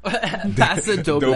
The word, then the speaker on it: paso doble: Paso Deblo. Diablo. – Paso Doble. – Doble. – Not a Spanish.